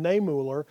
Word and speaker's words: Niemöller: I've worked all week on pronouncing that name, Niemöller.